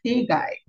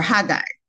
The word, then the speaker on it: Haggai: This morning I woke up and I was just there reflecting, and then I went into the book of Haggai, or Haggai, pronounced the song.